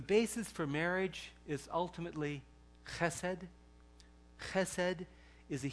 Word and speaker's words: Chesed: Chesed is the way to pronounce it.